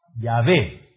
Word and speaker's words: Yahvé: Pero ¿cuál es la pronunciación más exacta del hebreo? En lugar de Jehová, ¿cuál es? Yahvé, ¿verdad? Yahvé es el Dios de pacto, el Dios que se reveló a Moisés.